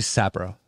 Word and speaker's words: Sapporo: It's actually Sapporo.